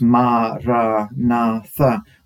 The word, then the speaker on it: Maranatha: and to say the word in four syllables, Maranatha, which means "come, Lord, come," and to use that as a prayer to still your mind.